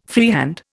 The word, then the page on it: freehand: Indian